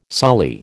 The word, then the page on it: sully: American English